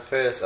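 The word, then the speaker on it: Fares: I have Instagram, Ferris Asi, that's my name.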